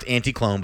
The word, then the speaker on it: Anticlon: I'm assuming it's pronounced Anticlone. – It is pronounced Anticlone, but it's spelt Anticlon. – Anticlon, all one word.